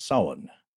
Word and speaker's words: Samhain: I'm working on getting him his own social security number, but it's spelled Samhain, but it's pronounced Samhain.